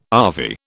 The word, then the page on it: ave: British English